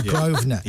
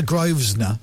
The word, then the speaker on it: Grosvenor: And you wanted to go, it's a silent S, dude. It's the Grosvenor. – Yeah. – It's not the Grosvenor.